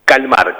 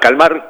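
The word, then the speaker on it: Kalmar: ¿Calmar o Calmar? ¿Cómo la pronunciamos? – Calmar, Calmar con K.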